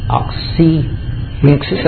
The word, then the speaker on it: Oxyrhynchus: Oxyrhynchus, that's it. Sounds like an ox rink, yeah.